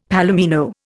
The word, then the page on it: palomino: Indian